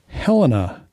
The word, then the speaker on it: Helena: Helena is how you pronounce the capital of Montana.